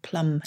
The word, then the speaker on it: plumb: I guess it's still plum. No, it's still it's plum as in plum line with a B on the end, not as in plum pudding.